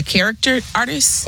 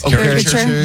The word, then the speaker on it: caricature: I would like I can't ever pronounce this word, the character artist. – Caricature.